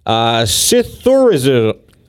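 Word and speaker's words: psithurism: Scytherism is an actual name of rustling leaves.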